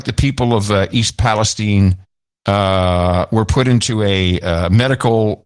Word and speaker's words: Palestine: The people of East Palestine. And why is it Palestine and not Palestine, even though you spell it the same way? – That's the way they pronounce it there.